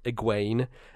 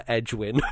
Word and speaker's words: Egwene: So there's a character in the Wheel of Time who's called Egwene, and I called her Edgewin for like the first three books in my head, and I'm like, I'm not changing this. I'm not gonna look at any pronunciation guide. There's nothing here. I'm just gonna say Edgewin. That's how it's said.